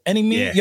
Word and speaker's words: Any Means: Is that how you say it? Any Means? Yeah. Yo, this whole time I was saying Any Minds. That ass. I didn't know it was Any Means.